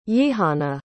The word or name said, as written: yihana